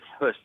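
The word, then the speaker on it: Höss: Rainer Hess. Yeah. – Huss, it's pronounced.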